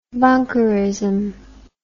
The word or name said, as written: bunkerism